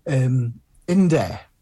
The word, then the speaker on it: Indeh: Indeh, which is I-N-D-E-H, which is a story of the Apache Wars.